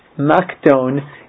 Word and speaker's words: Makdon: We pronounce it as an S sound, but it's a funny thing that in English we do that because it's not the actual pronunciation. It really is a hard C sound. It's Makdon. That's the true word.